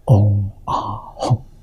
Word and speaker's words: 嗡啊吽: 发音是一样的，嗡啊吽，这三个字，这三个字是梵音，梵语的发音，意思是什么呢？